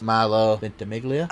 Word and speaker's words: Ventimiglia: She was in Boston Public, which other Gilmore stars guest in that series, Milo Ventimiglia. – You said it correctly.